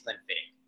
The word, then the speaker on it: Glenfiddich: It is Glenfiddich.